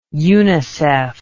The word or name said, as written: unicef